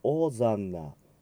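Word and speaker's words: Hosanna: そして、ホーザンナと書かれていますが、 H を発音しないので、オーザンナ、 in excelsis。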